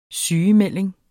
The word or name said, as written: sygemelding